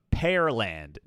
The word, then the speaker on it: Pearland: I might have said Pearland, but Pearland, Texas, like the pear, the fruit.